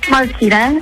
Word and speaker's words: Marquita: Like Martina only sounds more like a power drill. Marquita. I got you. – Yes, like Makita. Yep.